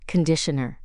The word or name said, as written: conditioner